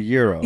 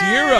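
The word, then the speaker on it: gyros: I may say it wrong, but it's a euro. – Yay! – It's euro, yes. It's euro.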